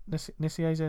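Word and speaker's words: Nicieza: Joe, I think when it started to sort of change, it was because Nisieza, is it Nisieza? Is that how you say his name? – Yeah, he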